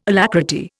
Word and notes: alacrity: Indian

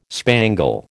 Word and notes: spangle: American English

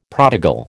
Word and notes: prodigal: American English

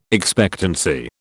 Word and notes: expectancy: British English

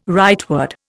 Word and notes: rightward: Indian

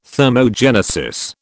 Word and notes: thermogenesis: British English

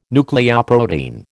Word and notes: nucleoprotein: American English

